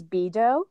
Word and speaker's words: Beadoh: And then me personally, as a human, if you just want to see what I'm up to, my name on Instagram is Bedo, and that's spelled B-E-A-D-O-H.